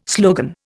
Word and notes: slogan: Indian

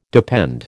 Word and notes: depend: American English

